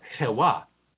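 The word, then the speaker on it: Chewa (חוה): In Hebrew, the Eve would be pronounced Chewa.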